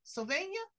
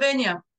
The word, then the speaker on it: Slovenia: Slovenia? How do you pronounce it? – Slovenia.